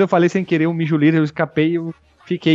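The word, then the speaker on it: Mjölnir: pronúncia certa é Mijulir, cara. O martelo do Thor. Eu vou ensinar para vocês que estão ouvindo esse podcast. Não é Mijulir, Mihaler, é Mijulir.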